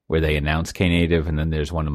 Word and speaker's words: Knative: I finally watched, you know, I heard the episode, I wasn't around, where you answered Tasty Meat Paul's question, what exactly is K-native or knative? Or as someone in our Slack channel has suggested, native, because the K is silent.